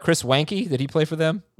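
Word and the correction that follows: Weinke: It's not it's not like W-E-N. – It is W-E-N. – No, it's spelled W-E-I. – Right. – But it's pronounced Winky.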